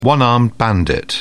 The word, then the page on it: one-armed bandit: British English